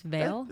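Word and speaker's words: Viall: Or No, I just said, so I've watched the Nick Vile season. – Right. – Also, I don't think his last name is pronounced Vile. – I think it's Vale.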